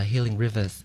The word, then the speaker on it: Healing Rivers: Trong cái chữ river mình thay chữ E bằng số 3. Thì nó sẽ đọc là Healing Rivers.